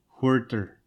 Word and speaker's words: Huerter: Clay Light and Hoiter? I don't know how to pronounce his last name. Kevin something. And then Draymond Green Light and Omari Spellman from Villanova. – Kevin Huerter, Maryland sophomore.